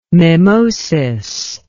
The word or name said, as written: mimosis